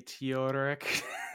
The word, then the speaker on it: Theodoric: Yeah, I think like we did Theodoric entirely wrong. I think it might be Theodoric. – Theodoric. – Yeah. Because so, the H is silent is what I keep seeing for like Germanic names, and these guys are definitely German, so it's subtly there.